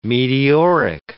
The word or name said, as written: meteoric